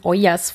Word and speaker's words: Ollas: Und da wollen wir euch als erstes mal die Ollas vorstellen. Habe ich das richtig ausgesprochen? – Sí, es correcto.